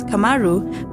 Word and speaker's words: KMRU: The work is by the fascinating and in many ways ubiquitous 25-year-old Kenyan sound artist Joseph KMRU, who releases music under the moniker pronounced as KMRU but spelled K-M-R-U.